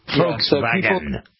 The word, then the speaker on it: Volkswagen: Supposed to be the V is an F. Volkswagen.